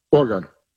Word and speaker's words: Oregon: Just do it in two syllables. Or, like O-R, and then gun, like Oregon. – Oregon. – There it is. – Oregon. O-R-G-U-N.